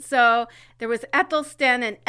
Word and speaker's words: Æthelstan: Like, I guess the official first king of England was Æthelstan? Is that how you pronounce it? – Mm-hmm. Yeah, that's how I've always pronounced it.